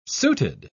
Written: suited

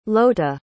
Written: Lota

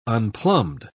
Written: unplumbed

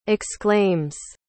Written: exclaims

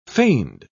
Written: feigned

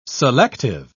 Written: selective

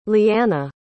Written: leanna

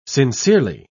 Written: sincerely

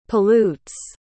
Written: pollutes

